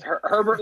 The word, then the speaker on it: Herbert: Is it Abe or Herbert? – No, it's Herbert. – Okay, so it's Herbert like Sherbert.